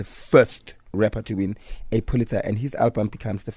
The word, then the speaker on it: Pulitzer: Pulitzer.